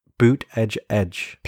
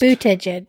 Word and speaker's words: Buttigieg: Okay, teach me. – Buttigieg. – Pete Buttigieg. – Yeah. – Because I'm sure you don't say it as slow as you just said it. – I can't say it fast. – Buttigieg. – Buttigieg. – Buttigieg. – Buttigieg. – In American, it's probably more like Pete Buttigieg. Buttigieg. – You're not saying it right.